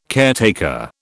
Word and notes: caretaker: British English

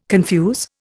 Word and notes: confuse: Indian

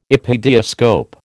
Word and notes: epidiascope: American English

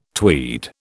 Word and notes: tweed: British English

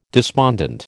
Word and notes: despondent: American English

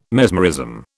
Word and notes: mesmerism: British English

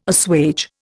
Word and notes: assuage: Indian

